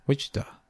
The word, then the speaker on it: Wichita: Wichita.